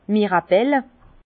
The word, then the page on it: d' Mirabel: Bas Rhin; Herrlisheim